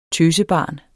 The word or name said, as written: tøsebarn